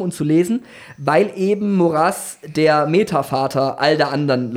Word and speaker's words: Maurras: Man sagt wirklich übrigens Maurras und nicht Maurras, weil Maurras aus Südfrankreich kommt, wo das ausgesprochen wird.